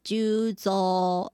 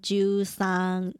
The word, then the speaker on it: 十三: 那木木警官他的日文发音呢，就叫做 Megure Juzo，而不是十三 Juzang，在名字里面呢会念作 Juzo。